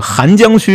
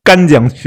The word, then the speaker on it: 邗江: 有名的几个例子，这个我不知道，黑明老师你知不知道？一个就是河南荥阳，呃，容易念成呃荣阳，还有扬州，呃，去扬州玩，这个邗江区，容易念成干江区。